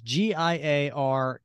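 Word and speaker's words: Giarrusso: And then there was a second part story, which is Mini Marvels, which by Chris, I'm going to say pronounce his name is Giriasso. Giriasso. It's Giarusso. So Giriasso.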